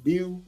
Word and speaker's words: beau: How do you say that? Beau? Beau? – Yeah, beau.